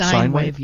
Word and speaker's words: Sine Wave: Is that how it's pronounced? – Sine Wave, yes.